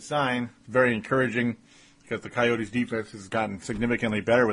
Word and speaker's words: Coyotes: And when the Wild score early at home against the Phoenix Coyotes, regardless of how good the Phoenix Coyotes have been, Coyotes, I'll just say it that way from now on. I've been saying it wrong.